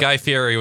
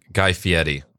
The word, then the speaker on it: Guy Fieri: The guy or the that looks like Guy Fieri with no facial hair or eyebrows. Excuse me, Guy Fieri. I mispronounced that. It's on the record forever.